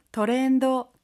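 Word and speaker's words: 트렌도 (トレンド): 트렌드, 트렌도, 트렌드, 경향인데요. 혹시 발음 팁 있을까요? – 하이, 그때 소리에 주의했으면 하는데요. 미음 받침 대신 무, 드가 아니라 도로 발음합니다.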